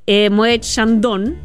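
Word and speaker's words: Moët & Chandon: Y la historia detrás de eso en realidad es notable porque él ocupaba siempre en los videos de música Moët Chandon.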